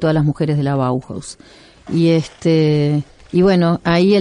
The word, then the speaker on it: Bauhaus: Al que me pide la pronunciación es Bauhaus, así como suena. – Bauhaus.